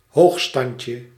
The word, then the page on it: prestige: American English; IPA : /prɛˈstiːʒ/; IPA : /prɛˈstidʒ/